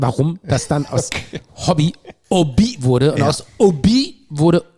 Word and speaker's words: Obi: Und die können ja das H nicht aussprechen. Ja. Ne? Die sagen ja statt... – Stumm. – Statt Hobby sagen die ja Obi.